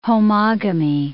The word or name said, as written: homogamy